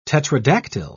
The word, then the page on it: tetradactyl: British English